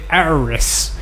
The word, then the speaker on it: Auris: I think it's A-U-R-I-S. But whenever I take it to the garage, I'll say Auris on the phone and say, do you mean Yaris? No, it's called an Auris.